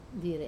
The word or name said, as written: dire